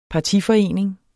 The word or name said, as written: partiforening